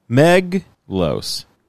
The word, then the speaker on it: Meglos: Meglos. Guys, that's how you say it.